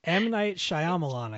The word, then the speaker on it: Shyamalan: M. Night Shyamalan, I think, is the proper pronunciation.